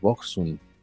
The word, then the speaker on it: Boksoon: Este, estoy viendo que alguien nos hizo favor de poner cómo se, cómo se pronuncia Boxon, este, y es como, como con una gema, que es Boxun.